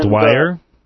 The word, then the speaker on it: Dwyer: I can't pronounce the last name, D-W-Y-E-R. – Dwyer? – I guess it's Dwyer. I guess that's it.